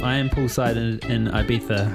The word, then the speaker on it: Ibiza: Is that how you say it? Ibiza? – Yes. I just got to Ibiza and I'm ready to party.